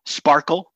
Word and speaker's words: SPARQL: Sparkle, which is spelled SPARQL, which is an RDF query language.